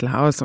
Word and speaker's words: Laos: Korrekt ausgesprochen, glaube ich, heißt es eigentlich Lao, aber im Deutschen gebräuchlich ist Laos und so kommt es mir heute auch einfacher von den Lippen, deswegen werde ich Laos sagen.